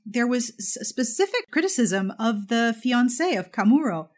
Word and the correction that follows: Komuro: Am I saying that incorrectly? Is it Kamuro or Kamuro? – Komuro. – Komuro. Thank you.